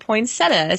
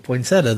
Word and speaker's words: poinsettia: And some call it poinsettia. So I think it depends on what part of the country you live in.